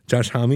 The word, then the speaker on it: Homme: Josh Homme? Homie? – Josh Homme? – Josh Homme, is that how you say it? – Yeah.